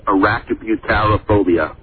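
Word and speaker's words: arachibutyrophobia: Well, the pronunciation we were given when we had to say this word in a production was arachnobutyrophobia.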